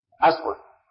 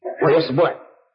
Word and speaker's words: إصبع: نعم يا أخي انطق بها بالحركات الثلاث بالهمزة أصبع. لا لا نعم أصبع وأصبع وإيش وإصبع.